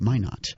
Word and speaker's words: Minot: Thanks to our listener in Minnesota for the correct pronunciation of Minot.